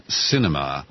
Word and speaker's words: Sinema: Sinema. – Yes. – Not cinema like a film. It's spelled S-I-N-E-M-A.